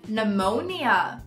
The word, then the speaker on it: pneumonia: Em inglês tem essa escrita parecido, mas ele é um silent letter. O P fica pneumonia. Repeat with me. Pneumonia.